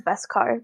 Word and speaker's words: Beskar: Is it with a Beskar. V or a B? Beskar? – B-E, I think. Is it Beskar or Beskar? – I think it's Beskar. That's how they pronounce it.